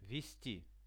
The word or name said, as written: vesti